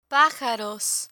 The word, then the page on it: pajaros: Spanish